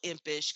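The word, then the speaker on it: Empish: And I'll spell Impish because I know my name is quite unusual.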